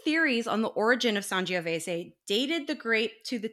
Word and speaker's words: Sangiovese: While there was not a lot about this particular wine, there's quite a bit to say about Sangiovese. And it's pronounced that way. It's not Sangiovese.